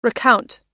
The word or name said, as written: recount